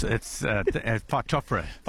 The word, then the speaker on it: Phytophthora: Phytophthora? – Yeah, it's Phytophthora. – Phytophthora.